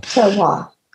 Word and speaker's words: terroir: And certainly when you talk about with the grape industry, Terroir, is that how you pronounce it? I always have a hard time with that. – Terroir.